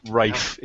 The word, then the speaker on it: Ralph: There's a really cool sci-fi movie with Ralph Fiennes. Is it Ralph? I can never. – Rafe. It's pronounced. – Rafe. Rafe Fiennes.